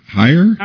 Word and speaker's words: Hir: It's called H-I-R. First of all, how is that pronounced? Her? Hire? – It's pronounced Hir. – Hir. – H-E-R-E, spelled H-I-R.